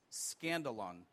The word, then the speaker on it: skandalon: You know, the word that is used for offense in the Greek is a word, it's pronounced skandalon, which actually is a word that we derive the word scandal from.